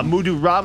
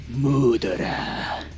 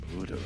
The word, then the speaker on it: Muda Ra: You got to say it with a Jamaican accent. It's a Mudu Ra, man. – No. Muda Ra. – Muda Ra. – Because then when you say it like that, it sounds like murderer.